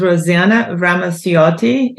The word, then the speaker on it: Rosana Ramacciotte: Like you said, my name is Rosana Ramacciotte. It's very Italian, hard to pronounce, but you did a very good job because you spoke more Italian than American when you spoke about my name, my last name.